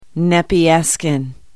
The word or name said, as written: nepeuskun